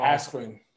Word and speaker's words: Askren: Askren.